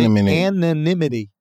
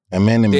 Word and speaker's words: anonymity: But he still has had a air of like, and I'm trying to pronounce this word, it's in my mind. Anonymous? Anonymity? – It's such a hard word to say. Anonymity. – Anonymity. – There you go. – Anonymity.